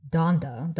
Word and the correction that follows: Dodona: Donda. – No, wait, I said it right the first time.